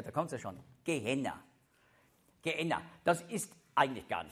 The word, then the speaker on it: Gehenna: Gehenna ist einfach griechisch, so ein bisschen griechisch ausgesprochen, das hebräische Gehenna. Und das heißt Tal Hinnom. Gehenna oder Gehinnom. Das ist ein Tal außerhalb der Altstadt von Jerusalem.